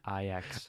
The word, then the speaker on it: Ajax: battle with I'm going to say Ajax because my uncultured New Zealand upbringing would lead me to do so. How do you say that again? – Ajax.